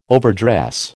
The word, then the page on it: overdress: American English